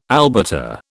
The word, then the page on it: albata: British English